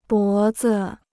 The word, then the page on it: 脖子: bó zi